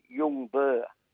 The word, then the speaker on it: Ljungberg: Is it Ljungberg? – Yeah, it's Ljungberg. – Ljungberg. See, silent G.